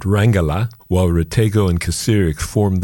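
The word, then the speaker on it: Kacirek: It's a duo consisting of German electronic experimental drummer Sven Kasirek, that's K-A-C-I-R-E-K, Sven Kasirek, and singer-songwriter and instrument maker Olaf Rategó, R-A-T-E-G-O.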